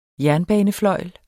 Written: jernbanefløjl

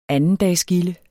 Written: andendagsgilde

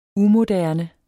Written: umoderne

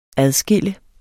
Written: adskille

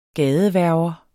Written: gadehverver

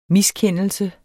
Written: miskendelse

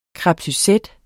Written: crepe

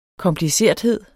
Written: komplicerthed

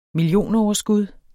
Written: millionoverskud

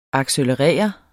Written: accelerere

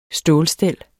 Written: stålstel

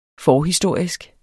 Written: forhistorisk

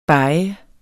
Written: beget

